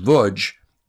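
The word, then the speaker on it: Łódź: and it was called The Chronicle of the Lodz, L-O-D-Z, pronounced Vudge, ghetto.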